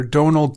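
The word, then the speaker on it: Domhnall: The right way to say it is Donal.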